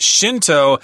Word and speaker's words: Shinto: Shinto is Shen Dao in Guoyu in Chinese. So that's the kanji there, Shen Dao, but pronounced Shinto in Japanese, at least the way the Americans would say it.